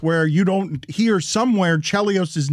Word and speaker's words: Chelios: I've always said Chris Chelios. That is my bad. – You made him a cereal. – His daughter is actually my age. She went to Northwestern. I always thought it was Chelios.